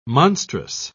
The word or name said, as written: monstrous